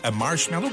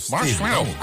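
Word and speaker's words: Marshmello: La preuve que vous êtes au meilleur de la musique, c'est Marshmallow, comme ça qu'on le prononce. – Ouais, tu l'as bien. – Marshmallow.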